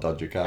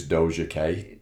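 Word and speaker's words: Doja Cat: I think it's Doja K or something like that. But it's spelled D-O-J-A and then... – Cats. – C-A-T. So I said, like, Dodger Cat.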